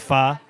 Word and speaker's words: pho: Pho is spelled P-H-O, but it's pronounced pha.